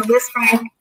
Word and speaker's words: Lisfranc: Travis Etienne has, it's a Lisfranc injury, just that is the pronunciation. Yeah, has a Lisfranc sprain, and people are like, "Oh, that's bad.